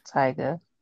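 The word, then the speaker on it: Tyga: I was gonna say, isn't his name Tiga? And that's like no, but it's Taiga. well, it's Taiga on here. It's, it's pronounced Taiga.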